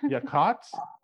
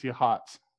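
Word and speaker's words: Yachats: So the highlight was also at that restaurant where we finally were like, okay, is your town pronounced Yakots or Yachots or Yashits? Yashits. And they were like, it's Yachots. It's like a silent C.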